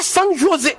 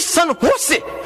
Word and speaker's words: San José: On dit pas San José. On dit San José. Ce J se prononce comme un H.